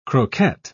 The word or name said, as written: croquette